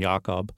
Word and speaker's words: Jakob: He published his first novel at age 26 in 1959 called Speculations About Jacob, or with the German pronunciation, Jakob, same year as The Tintendrum.